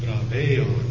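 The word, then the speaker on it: braveon (βραβεῖον): The accent there is a circumflex accent over the iota, and it's pronounced braveon. Braveon.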